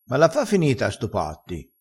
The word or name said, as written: stopassi